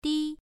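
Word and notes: di: tone 1

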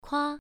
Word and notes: kua: 1

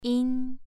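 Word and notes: yin: tone 2

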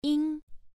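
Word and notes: ying: tone 1

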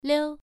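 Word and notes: liu: tone 1

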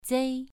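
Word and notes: zei: tone 2

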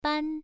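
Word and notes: ban: tone 1

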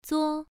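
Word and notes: zuo: tone 1

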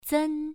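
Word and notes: zen: tone 1

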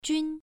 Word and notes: jun: 1

